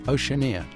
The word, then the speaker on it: Oceania: The album is divided in three sections: Urbanoia, Americana, with a K, and is it pronounced Oceania? – Oceania. – Oceania. How do these three sections fit together, and what was your goal with this project?